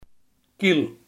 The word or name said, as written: wig